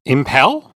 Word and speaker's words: impel: Well, that's not how you would... The accent is on the M. – Impel? – Yeah.